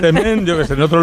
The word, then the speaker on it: Msemen: Es una palabra complicada, es M-S-E-M-E-N. Msemen, msemen, ¿vale? Y se pronuncia como lo ha hecho Hakima. Pero nunca, nunca se pronuncia como lo vamos a escuchar en boca de este tiktoker, porque puede ocasionar confusiones.